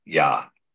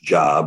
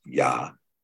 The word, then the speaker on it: Yah: And they say it's pronounced Jah, but we know there's no J in Hebrew.